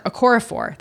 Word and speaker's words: Okorafor: Okorafor.